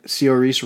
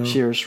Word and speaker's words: Saoirse: Frances McDormand for Three Billboards Outside Ebbing, Missouri, and Saoirse Ronan for Lady Bird. And oof. I butchered her first name because it's Saoirse. I don't know how you say it. – Saoirse Ronan.